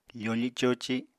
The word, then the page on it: fofi-coci: apatani